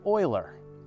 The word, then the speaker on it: Euler: Euler is pronounced Euler.